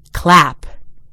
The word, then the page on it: clap: American English